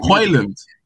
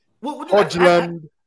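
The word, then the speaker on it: Højlund: It's Hojlund. Rasmus Hojlund. – Hojlund. That's easier to say than what I said. – Bro, the J is silent.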